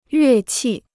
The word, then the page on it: 乐器: yuè qì